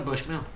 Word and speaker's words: Bushnell: Is it Bushnell or Bushmell? – Bushnell. B-U-S-H-N-E-L Hospital.